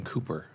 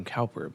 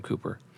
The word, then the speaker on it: Cowper: His name was actually pronounced William Cooper, and a lot of people pronounce it William Cowper, but it's William Cooper.